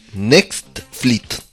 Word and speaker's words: Netflix: No te rías, se dice así, Netflix.